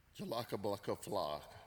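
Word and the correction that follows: Jólabókaflóð: Jolaka Baka, I think you added a K in there somewhere. Okay? Kevin Kringle has pastored a mega church in America. Okay, I'm gonna give you a shot at it. Okay, now don't add an extra K, okay?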